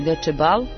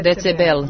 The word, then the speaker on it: Decebal: Ovi kažu u Rumuniji Decebal. – Decebal. – A mi kažemo Decebel.